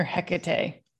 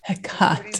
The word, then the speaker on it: Hecate: Hecate. – Yes. – Or Hecate, or however you want to say it. – Emphasis on the ka. – Hecate.